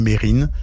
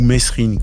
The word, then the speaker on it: Mesrine: Jacques Mérine, comme le prononcent ses proches, ou Messrine, comme le disent de nombreux médias et policiers.